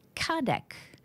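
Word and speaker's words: Cardak: Kardak, I think it is. It's C-A-R-D-A-K, but I think it's a hard C.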